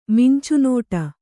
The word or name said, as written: ಮಿಂಚುನೋಟ